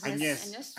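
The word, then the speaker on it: Agnès: De, ¿Cómo se pronuncia? Añez. – Añez. Añez, ¿verdad? ¿verdad? Sí. Sí.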